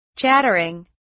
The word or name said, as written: chattering